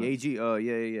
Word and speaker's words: Gaethje: And people were really doubting it because that Gaethje guy, I forget how to say his name. But Gaethje, yeah.